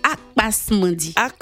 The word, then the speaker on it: Akpas Mandi: Ça s'appelle Akpas Mandi. Est-ce que je l'ai bien prononcé, Merveille? – Vous l'avez bien prononcé. – Akpas Mandi.